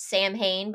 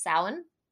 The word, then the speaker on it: Samhain: And then you get to Samhain, which some people in their head pronounced Samhain, but it's pronounced Samhain, at least in the American pronunciation of it.